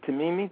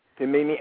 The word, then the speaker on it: Timimi: Dr. Jackson is the author of many peer reviewed articles and book chapters, most recently contributing to to Timimi. Did I say that right? – Yes, that's right. – Timimi and Jonathan Leo's Rethinking ADHD.